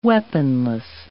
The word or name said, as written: weaponless